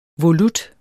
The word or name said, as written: volut